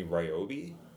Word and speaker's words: Ryobi: But it was from the company Ryobi.